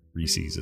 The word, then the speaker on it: Reese's: Here in the Western states, we always said Reese's, but I think you're actually saying it right. Reese's is the right way to say it.